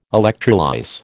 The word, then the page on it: electrolyse: American English